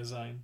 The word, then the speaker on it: Zayn: Zayn.